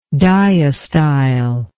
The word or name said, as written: diastyle